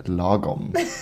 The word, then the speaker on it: Lagom: Und äh, zwar heißt der Trend Lagom. – Richtig. – Und das wird auch so ausgesprochen, liebes ZDF, Lagom. genau. – Und nicht anders.